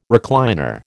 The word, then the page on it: recliner: American English